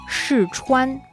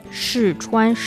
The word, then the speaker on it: 试穿: 试穿. – 试穿. – Yeah. – Okay. – 试穿, 试 is the fourth tone. 穿 is the first tone.